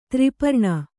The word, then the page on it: ತ್ರಿಪರ್ಣಿ: tri parṇi